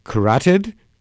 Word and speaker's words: carotid: And when she came to see me for carotid, is that the pronunciation? Carotid, the carotid arteries. I think the dictionary said that was the pronunciation.